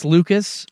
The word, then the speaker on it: Lukes: I mean, that Nathan Lucas guy, it's spelled Lukes, but it's Lucas.